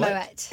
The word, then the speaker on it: Moët: Moët. – It's Moët. – You pronounce the T.